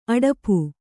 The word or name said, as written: ಅಡಪು